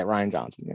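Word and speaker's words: Rian Johnson: Yeah, Ryan Johnson.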